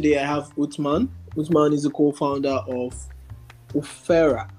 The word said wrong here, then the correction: Ofra: I don't know if I'm pronouncing that correctly. – No, you're not. – Okay, what is the pronunciation, please? – Ofra. – Ofra. Oh. – Yes.